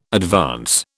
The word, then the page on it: advance: British English